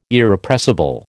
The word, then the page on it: Irrepressible: American English